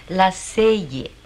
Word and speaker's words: Lasselle: Se escribe Lasselle, se pronuncia Lasselle. Héctor, tú que estudias francés, ¿verdad que es Lasselle? – Bueno, sí. Es como dice mamá.